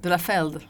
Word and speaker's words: de la Feld: I said it wrong. Ondine de la Feld. – De la Feld.